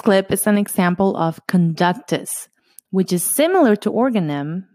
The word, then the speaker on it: conductus: And the word conductus does sound like conductor a little bit. It's spelled very similar, C-O-N-D-U-C-T-U-S.